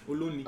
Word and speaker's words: Oloni: Is that you say her name? Oloni. – Yes, that's her name.